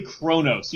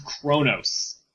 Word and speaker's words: Kronos: It's clearly Kronos. You don't need to know a whole lot about Star Trek to know it's pronounced Kronos.